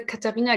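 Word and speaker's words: Catharina: Ich heiße Katharina Giffey und Katharina schreibt man mit C, weil das ein kleiner Familiengag ist.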